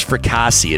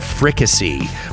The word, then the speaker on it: fricassee: I thought it was fricassee. It's chicken fricassee.